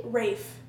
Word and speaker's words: Ralph: It's pronounced Ray? – Rafe. – Rafe? No, it's not. That's a lie. – Look it up. – Voldemort is pronounced Rafe? – Rafe. – Rafe? – Look it up right now. That's going to bother me so much. – He pronounces his name Rafe Fiennes.